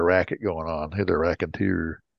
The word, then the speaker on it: raconteur: It's raconteur.